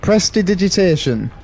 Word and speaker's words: prestidigitation: Can I use prestidigitation? – Can you pronounce slam... it? – That's homebrew. – Prestidigitation.